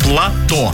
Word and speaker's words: плато: Плато. Именно на О ударение.